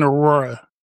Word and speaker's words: Aurora: It was in Aurora. Am I saying that right? – Yeah, you are.